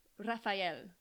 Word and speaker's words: Rafael: Wait, I'm like saying it with an American accent. You say your name. – Rafael.